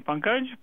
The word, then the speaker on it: Pankaj: Hi there, this is Pankaj. How are you? – Splendid. And how should we address you, sir? – You can call me Pankaj.